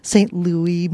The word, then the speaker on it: St. Louis: St. Louis, Missouri.